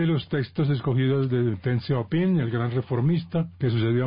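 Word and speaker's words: Deng Xiaoping: El libro rojo y además también se pronuncia Ten. – Ten, Ten. – Ten Xiaoping. – Ten Xiaoping.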